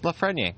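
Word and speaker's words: Lafrenière: I'll pronounce it the way that they say it on the Rimouski broadcast, which is Lafreniere.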